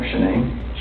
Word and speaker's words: Shanann: And I don't want to pronounce her name wrong, Shanann? – Yep.